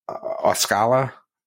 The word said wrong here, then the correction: Ocala: It was by Tampa, whatever that is. – Are you talking about Ocala? – Ocala, yeah, or, sure.